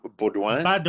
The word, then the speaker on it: Baudouin: Baudouin.